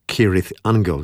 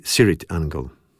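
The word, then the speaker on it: Cirith Ungol: Poprawna wymowa nazwy to Kirith Angel, jednak członkowie zespołu wymawiają ją z miękkim C na początku, czyli Sirith Angel.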